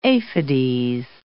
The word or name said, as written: aphides